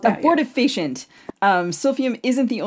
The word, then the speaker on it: abortifacient: Uh, Abortifacient? Am I saying it right? – Um, I've heard people say abortificant or abortifacient. – Okay, I guess I'm going to go with abortifacient.